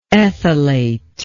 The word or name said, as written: ethylate